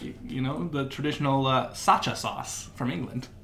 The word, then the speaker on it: Sriracha: This is Sriracha. – Sriracha? – Sriracha. – Sriracha. The first R is silent.